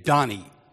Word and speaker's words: Dani: He was an archaeologist named Dani, spelled D-A-N-I, short for Daniel, but pronounced like Donny Osmond rather than Danny DeVito.